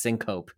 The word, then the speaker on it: syncope: So loss of consciousness in the medical community is known as syncope, S-Y-N-C-O-P-E, a word that does not look the way that it's pronounced, but trust me, it's syncope. And wait, not syncope? – Yeah, don't call it syncope.